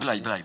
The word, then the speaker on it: Blaye: Et évidemment, en général, plus au nord, donc au nord de Blaye, oui, de Blaye, je sais pas comment on doit prononcer. – Blaye. – Au nord de Blaye, oui, c'est ça.